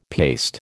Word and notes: paste: American English

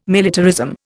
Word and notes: militarism: Indian

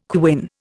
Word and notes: quin: Indian

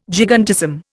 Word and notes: gigantism: Indian

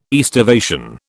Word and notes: aestivation: British English

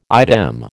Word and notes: idem: American English

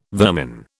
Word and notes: vermin: British English

